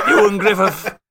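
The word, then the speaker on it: Ioan Gruffudd: No one knows how to say his name. Ewan Griffith. Owen Grifford. Is that how you say it? yeah. – Ewan Griffith. Close enough. – The Welsh one.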